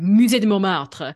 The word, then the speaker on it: Musée de Montmartre: I can't say that correctly in any way now. I'm like Musée de Montmartre. Um, they have they've got sounds correct to me.